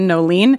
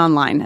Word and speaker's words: Noeline: Yes, you can find me so most of my social handles are all my first and middle name, which is Kristin Noeline. I'll spell it for you. It's KristinNoeline. It's not Kristin Online, as many people think my name is.